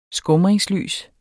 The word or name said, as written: skumringslys